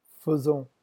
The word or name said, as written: faisons